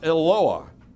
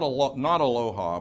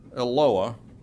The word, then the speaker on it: Eloah: It's pronounced Eloah, not Aloha, but Eloah.